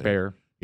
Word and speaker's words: Baier: The news shows are like Brett Baier, or Bear, however you say his name. – Bear. – You know, I think it's Bear.